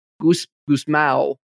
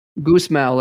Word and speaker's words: Gusmão: Gusmão. – I'll accept Portuguese. Either pronunciation. – Gusmão, like Luiz Guzmán.